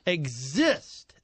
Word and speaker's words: Xist: It's the, how do you pronounce that? – Exist. – X-I-S-T.